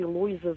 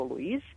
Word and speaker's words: Louise: Louise Glück, ja ću ponekad izgovoriti Louisa zato što su je transkribovali u nekim trenucima i kao Louisu, ali ona je zapravo Louise.